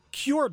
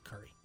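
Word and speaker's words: Kure: It goes, well, I see Cure Beach. I'm like, no, that's pronounced Curry.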